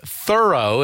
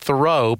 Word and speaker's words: Thoreau: And just by the way, for those of you who are a bit pedantic about things out there, we both know that Henry David Thoreau pronounced his name Thoreau and not Thoreau, but you know, old habits die hard for me.